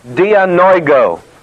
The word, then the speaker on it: dianoigo: The word opening is the Greek word dianoigo. I'll write it up here. D-I-A-N-O-I-G, long O.